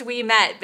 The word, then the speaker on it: Ouimette: My last name is pronounced We Met, but you spell it O U I M E T T E.